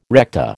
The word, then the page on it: recta: American English